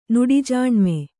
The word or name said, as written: ನುಡಿಜಾಣ್ಮೆ